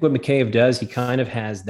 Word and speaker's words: Mikheyev: You mentioned, and we've been talking about free agent coming out of Toronto, obviously Ilya Mikheyev, and I'm saying his name wrong. – Mikheyev.